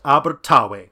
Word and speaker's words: Abertawe: In Welsh, Swansea is pronounced Abertawe,